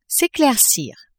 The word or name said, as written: s'eclaircir